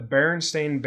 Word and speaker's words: Berenstain: The Berenstain Bears books. It's spelled B-E-R-E-N. Everyone's fine with that. In real life, it ends S-T-A-I-N. In everyone's brain, it ends Stein, like the Jewish name, Berenstain Bears.